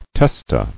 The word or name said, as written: Testae